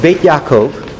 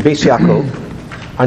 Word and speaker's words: Beit Yaakov: The back of the first page is a book called Beit Yaakov, in an Ashkenazi pronunciation, Beis Yaakov, on Parshat Pekudei, which is not this week's Parsha.